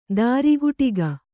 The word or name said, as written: ತ್ರಿಕಟುಕ